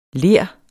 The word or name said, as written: LERS